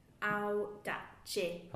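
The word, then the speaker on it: Audace: I was there and I wrote it down phonetically. Aldacci.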